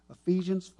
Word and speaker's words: Ephesians: Ephesians 5, did I say that right? Yeah, 25 through 27.